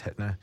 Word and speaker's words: Hittner: Yes, Hittner, I said that right.